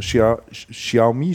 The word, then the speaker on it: Xiaomi: Xiaomi, spricht man den, glaube ich, aus, richtigerweise ähm, und Lenovo und ähnliche.